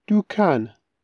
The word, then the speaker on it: دووکان: یه کلمه جالب داره که توی فارسی هم بعضا استفاده میشه ولی خب توی کردی تلفظ متفاوتی داره دوکن دوکن دوکن حالا وقتشه بریم سراغ دیالوگ این اپیزود هیوا دوکانی با پیر لکوه دوکانی با پیرم لگرکی انکاویا چۆن دەتوانم پچمە دووکانی باپیرەت؟